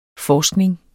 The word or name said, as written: forskning